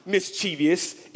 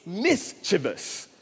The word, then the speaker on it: mischievous: You thought this was a four syllable when it came, when it really has only three. It's not mischievous, it's mischievous.